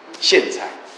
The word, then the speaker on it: 苋菜: 那姓蔡是上海菜，姓蔡，姓蔡，或者有时念成苋菜，一个草字头下面一个苋，苋菜，应该是发音成苋，不是姓啊。